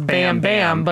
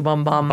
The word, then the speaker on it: Bam Bam: Yeah, I mean, if you look at it, you'd think it was Bam Bam, Bam, but the way she pronounces it in the song is definitely closer to Bum Bum.